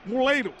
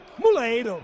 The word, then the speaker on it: Moleiro: Moleiro. – Moleiro.